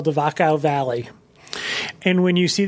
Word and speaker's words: Wachau: It's spelled Wachau, W-C-H-A-U, I believe. They pronounce it Wachau.